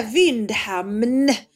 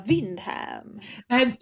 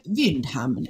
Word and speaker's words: Vindhamn: Ska man säga Vindhamn och liksom betona enet eller ska man säga Vindham? Nej, Vindhamn. Exakt Vindhamn. så som det, som det stavas.